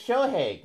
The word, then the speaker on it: Shohei: It's Shohei.